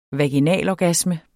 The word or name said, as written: vaginalorgasme